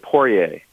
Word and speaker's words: Poirier: Yeah, you, the second one was right, Poirier. – Okay, so Poirier. – It's actually not like, if we were, it's a French name. He's from Louisiana, so it should be Poirier. – Yeah. – But, you know, they've anglicized it, as we say, so that's how he pronounces it.